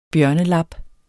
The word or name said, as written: bjørnelab